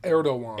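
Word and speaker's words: Erdoğan: Recep Tayyip Erdogan.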